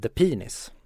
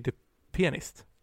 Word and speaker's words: The Pianist: Vi ska kolla på och vi ska prata om The Penis. – Nej, The Pianist. Ja, men när alla ska uttala The Pianist så uttalar de alltid The Pianist snabbt och då blir det The Pianist.